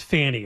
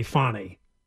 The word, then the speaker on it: Fani: Today, the Fulton County DA, and her name is not pronounced Fanny. It's apparently Fanny. I like Fanny better. – F-A-N-I? – Yeah, it's pronounced Fanny.